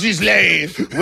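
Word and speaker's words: Ghislain: G H I S L A I N. I say Gislane. – It's Gislane. Okay. – Yeah. Well, okay, then it would be Denis Gislane. Okay. Denis Gislane would like to know.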